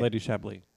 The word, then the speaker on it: Chablis: Evie as the Boogeyman, Jinx as Judy Garland, Jada as Lady Chablis. Am I saying that right? – Yeah. Okay. Lady Chablis.